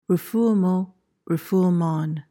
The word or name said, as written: refoulement